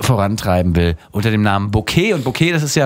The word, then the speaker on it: Bokeh: Ein Bouquet, richtig. Wird genauso ausgesprochen, bloß ein bisschen anders geschrieben. – Nee, Bouquet. – Bouquet schreibt man ja fast so, wie man spricht. B-O-K-E-H.